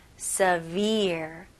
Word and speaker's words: severe: 네, 여기서 스펠링은 S-E이지만 발음은 severe. severe. 그리고 R 발음까지 마무리 잘 해주셔야 됩니다.